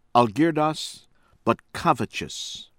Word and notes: Algirdas Butkevicius: American English

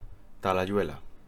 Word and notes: Talayuela: Spanish

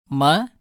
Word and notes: ம: Tamil

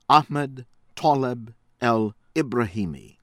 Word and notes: Ahmed Taleb El Ibrahimi: American English

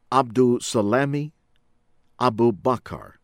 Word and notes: Abdulsalami Abubakar: American English

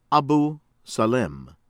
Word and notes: Abu Salem: American English

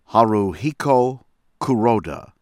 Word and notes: Haruhiko Kuroda: American English

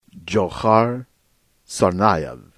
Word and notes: Dzhokar Tsarnaev: American English

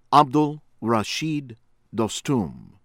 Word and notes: Abdul Rashid Dostum: American English; Afghanistan

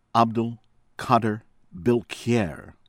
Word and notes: Abd Al-Qadir Bilkhayr: American English

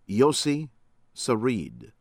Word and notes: Yossi Sarid: American English

